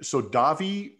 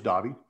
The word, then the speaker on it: Dawie: So I had Davy Vanderwalt is spelled Dawie. Didn't know it was Davy.